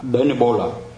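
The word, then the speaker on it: Denebola: Deneb Al-Asad thì họ đọc là Danebola.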